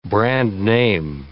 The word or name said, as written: brand-name